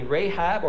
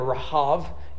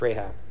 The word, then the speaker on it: Rahab: Only Rahab, or Rahav, you may hear her name pronounced because it's actual Hebrew. In English, it's Rahab.